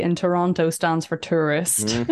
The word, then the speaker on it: Toronto: They were like, we know that you're a tourist because you say Toronto. You don't say Toronto.